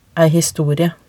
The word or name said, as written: ei historie